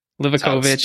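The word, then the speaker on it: Livaković: I mean, I'm going to butcher it either way, but I think it's Livakovic. – Livakovic.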